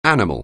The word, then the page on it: animal: anglais